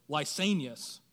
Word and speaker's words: Lysanias: Lysanias, he was a tetrarch as well.